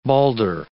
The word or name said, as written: Balder